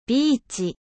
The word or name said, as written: ビーチ